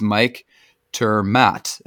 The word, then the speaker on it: Mike ter Maat: And again, our guest is Mike Termat.